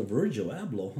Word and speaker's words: Virgil Abloh: The, man, speaking of, as you mentioned, Kanye, man, so Virgil Abloh, huh? Is that how you pronounce it? – Yeah, bro.